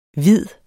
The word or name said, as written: hvid